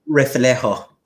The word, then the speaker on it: Reflejo: Reflejo.